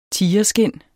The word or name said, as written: tigerskind